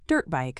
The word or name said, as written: dirt bike